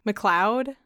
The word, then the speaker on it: McLeod: And after Sarah McLeod, I don't know how to say the actress's last name who played McLeod. Mm-hmm. I'm pretty sure that's how it's pronounced.